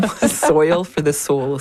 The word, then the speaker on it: Soil for the Soul: Alors Naomi, vous allez aussi vous investir, alors on va en parler parce que c'est un projet vraiment intéressant d'art écologique, est-ce que je le dis bien? Donc c'est Soil for the Soul. Excusez-moi pour l'accent. – C'est bon, Soil for the Soul, c'est voilà, bien ça. Voilà, vous le dites bien mieux que moi.